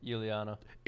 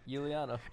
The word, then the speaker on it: Iuliano: Iuliano. – What? – Iuliano.